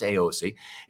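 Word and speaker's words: Eossi: it's e-o-s-s-i by the way mm-hmm. pronounced aoc it's a luxury vegan plant-based skincare products uh, it's us source hemp it's formulated and made right here in Colorado.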